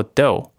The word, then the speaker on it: dough: dough 这个字啊，gh 不发音哦，它念作 dough，而且这个面团啊，不单只能用在披萨而已，大家喜欢的甜点啊、点心啊。